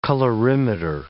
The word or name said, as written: colorimeter